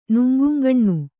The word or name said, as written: ನುಂಗುಂಗಣ್ಣು